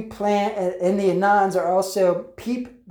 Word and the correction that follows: Pepe: the Frog, Pepe the Frog, actually, I should say that correctly.